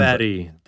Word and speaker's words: Magnus Lindberg: Magnus Lindberg. – Sounds Lindberg. – German. – Lindberg, that's the Swedish pronunciation.